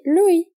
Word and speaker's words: l'ouïe: E l'ouïe, audição. Sim, essa é a mesma pronúncia, por exemplo, do rei Louis XIV.